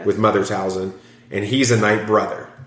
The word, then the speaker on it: Talzin: Well, he grows up on Dathomir as Mother Tazma's... – Talzin. – I can never get her name right.